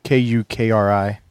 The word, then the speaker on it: kukri: It's spelled K U K R I.